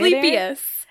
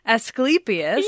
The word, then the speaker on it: Asclepius: Asclepius. – Asclepius.